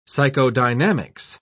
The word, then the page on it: psychodynamics: British English